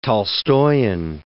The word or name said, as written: Tolstoian